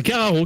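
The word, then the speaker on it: Karacho: Il s'agit de Carraro. Et à l'époque. – Tu veux dire ah. – Alors c'est Carraro que ça se prononce.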